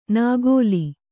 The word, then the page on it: ನಾಗೋಲಿ: Kannada